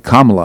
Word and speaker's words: Kamala: Kamala, I guess it's Kama with a la at the end.